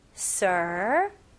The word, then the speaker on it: surprised: 여기서는 surprised, surprised, okay? 여기서는 S, 두 번째 S 발음을 약한 Z 발음으로 하시면 좋아요. Surprised, surprised.